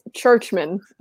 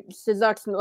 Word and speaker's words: Czuczman: His name is spelled C-Z-U-C-Z-M-A-N. Churchman. – That's a nope. – It's Churchman. – What? – C-Z-U-C-Z. And like in my head, when I type it out, I say Sizuxman, like it's a Churchman. Like, but yes, they're C-Z-U-C-Z-M-A-N.